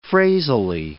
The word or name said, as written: phrasally